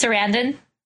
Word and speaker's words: Sarandon: I forgot who told me how it was, but uh, now, Chris Sarandon is that how you, that's how you pronounce his name? – Chris Sarandon.